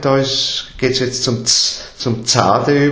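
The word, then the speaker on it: Tsade: Dieser Buchstabe heißt Zade und wird eben als Z ausgesprochen. Geschrieben wird aber selbst, also wenn man es ins Deutsche übertragt, schreibt man es eben mit TS, Zade.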